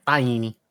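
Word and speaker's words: Tainy: I think it's T-A-I-N-Y or something. – Yeah. I'm going to say Taini. – Yeah, Taini.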